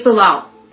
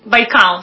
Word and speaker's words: Baikal: Correct my pronunciation. Lake Bilal. – Baikal.